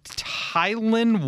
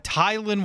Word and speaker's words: Tylan: Yeah, Tylan Wallace. – Yes. – Okay. – Some people pronounce it Tylan. I think it is Tylan. – Tylan? – Yeah. – Tylan. – Yeah, it's spelled T-Y-L-N.